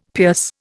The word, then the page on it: pierce: Indian